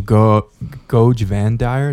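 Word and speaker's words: Goge Vandire: As the Imperium was thrown into another civil war because of Goj Vandyr, is that how you say it? – Yeah.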